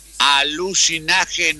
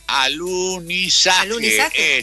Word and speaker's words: alunizaje: Alucinaje no se dice, alunizaje es, perdón.